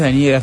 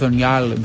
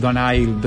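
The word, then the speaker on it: Donyale: Se inventó Doñal, que es como le decían Daniela en las notas, no es Daniela, es Doñal, Donail, Doñal.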